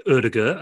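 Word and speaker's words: Ødegaard: Just on Odegaard, it looks as though he's off to Arsenal on a loan. We should be pronouncing it Odegaard, apparently.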